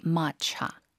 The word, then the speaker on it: matcha: 한국어로는 아까 설명 주신 대로 말차이지만 in English you would say matcha. – Matcha. – Yeah, matcha.